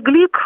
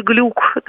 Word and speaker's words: Глюк: И в этом смысле и Луиза Глюк, или Глюк, да, и так и по-другому читается ее фамилия, и Гурна – это очень такие авторы компромиссные, не вызывающие активных споров, да, с обязательной программой.